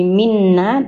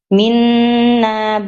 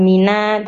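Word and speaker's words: minna (مِنَّا): Nah, di minna tadi kan bacanya itu mina gitu ya. Jadi tasdidnya hilang ya. Dan itu harus didengungkan dulu nggih. Minna bukan mina tapi minna adabun alim.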